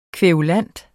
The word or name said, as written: kværulant